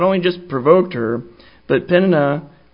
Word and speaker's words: Peninnah: I used to say Peninnah. I was trying to get my pronunciations correct, and it seems to be Peninnah.